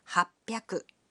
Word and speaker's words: happyaku: But the pronunciation can be a bit tricky. 300 is pronounced sanbyaku. 600 becomes ropyaku. And 800 is pronounced happyaku.